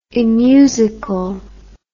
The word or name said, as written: immusical